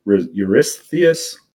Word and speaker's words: Eurystheus: Eurystheus. Eurystheus.